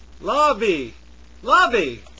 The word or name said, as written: lobby